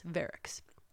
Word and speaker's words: Verux: It's spelled V-E-R-U-X, Verrick's.